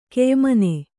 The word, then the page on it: ಕೆಯಿಮನೆ: keyimane; Kannada